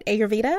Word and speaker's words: Ayurveda: You also have a background in Ayurveda. Did I say that right? Ayurveda? – Oh, yes.